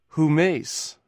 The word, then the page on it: humeis: greek